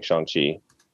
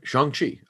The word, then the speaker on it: Shang-Chi: No, I definitely was saying Shang-Chi because they did Simu Liu had a tweet where he basically put out a pronunciation guide for all the characters and the movie. And after that, I switched to saying Shang-Chi. Him and the names of the actual actors too. – Like, I'm just surprised. No, because we corrected on names. Fair. But I guess I hadn't been saying that one right.